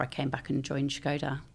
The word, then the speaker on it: Škoda: Yeah, so we have purposely started pronouncing it Škoda. So obviously we're a Czech company. And when you write the S, it has this little Czech accent over the top, which makes it pronounced Sh.